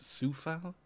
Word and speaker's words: Coufal: I dunked your Coufal, I think. Um, I that week pronounced Sufal.